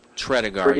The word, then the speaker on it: Tredegar: It's Tredegar. Tredegar. – Tredegar, yes.